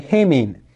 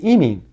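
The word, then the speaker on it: emin (ἡμῖν): In ancient Greek, seemingly, both would be emin.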